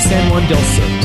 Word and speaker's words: San Juan del Sur: I've been there, and it's San Juan del Sur. – Still sir. – Yeah, okay. Regular, like, sir. Yes, sir.